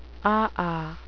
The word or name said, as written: aa